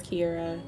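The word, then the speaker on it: Kiarra: They spell it like K-I-E-R-A or K-I-E-R-R-A. It's K-I-A-R-R-A, but, or they call me Kiera. I get that all the time.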